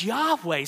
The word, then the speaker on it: Yahweh: Besides the fact that they've got God's name wrong, God's name is not Jehovah. It appears nowhere in Hebrew, Aramaic, or Greek. It's a made-up name. God has revealed himself through the Hebrew Bible as Yahweh.